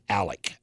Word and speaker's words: Alex: It's spelled Alex, but he pronounces it Alec.